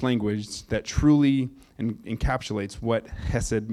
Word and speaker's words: hesed: I put pronounced hesed without the C, because you could just read that as chest. And the word is hesed.